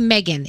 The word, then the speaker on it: M3GAN: If you get a chance to watch this trailer, the movie is called Megan. It's spelled M3GAN.